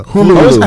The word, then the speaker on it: Hulu: It's Hula or Hula? – Hulu. – I always pronounce it wrong. – Hulu. – Hulu. Okay.